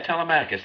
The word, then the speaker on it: Telemachus: You pronounced it differently, but Telemaeus. – Is that T-E... L-E-M-A something? What would come after that? C-H-U-S. – Oh, yeah, Telemachus.